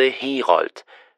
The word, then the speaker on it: Herold: Ich heiße Herold. Das schreibt man H-E-R-O-L-D.